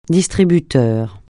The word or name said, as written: distributeur